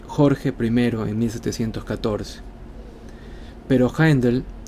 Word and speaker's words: Handel: Pronto seguido por el italiano Bononcini, Handel se aclimató plenamente en Inglaterra, cambió su nombre a George Handel, sin la diéresis, y pronunciado Handel.